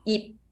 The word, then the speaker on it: Ip: Because, you know, in Chinese, no matter Mandarin or Cantonese, we pronounce the Y and I is the same pronunciation, it's E. So it's come together, it will be yip. But I have some student, they will have a problem, like they will always say yip.